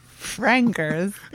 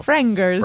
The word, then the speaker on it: Frengers: Fringers. – How do you spell that? – F-R-E-N-G-E-R-S. Oh, Fringers.